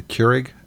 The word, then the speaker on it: Keurig: What is the Keurig? Is that how you pronounce it? The Keurig? – Yeah.